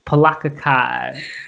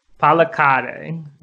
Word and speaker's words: Palakkad: Palakkad. It's in India, so. – Palakkad.